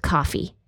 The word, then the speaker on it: Ko-fi: It looks like Ko-fi, but it's pronounced coffee.